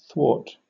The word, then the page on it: thwart: British English